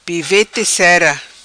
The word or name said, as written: Pivete cera